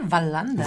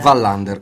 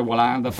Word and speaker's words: Wallander: Ma non si dice Wallander? – Wallander, confermo. – Sì, ma lasciatemelo dire Wallander, tanto è la prima ultima puntata.